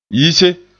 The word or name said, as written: yise